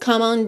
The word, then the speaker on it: come: Come, C-O-M-E. A gente não pronuncia esse E. Então, já pode juntar do M direto no on.